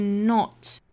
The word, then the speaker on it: not: 首先我们做英音的语音讲解，那我们来看第一个词要注意的呢，就是 not 这个词， N-O-T, 英音的发法呢是 not, o, not. You do not seek power, not, 后面的 t 的发音我们憋这么一下，不爆破哈。